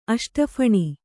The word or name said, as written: ಅಷ್ಟಫಣಿ